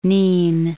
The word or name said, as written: Nin